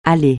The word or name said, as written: aller